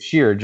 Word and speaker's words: Sheard: So I, I Sheard, Sheard, Jabaal Sheard. – Jabaal Sheard. I like him one because I can butcher his name for the next two or three years. – But what did you say at first? Shahid. – Shahid, Jabaal Shahid, Sharid.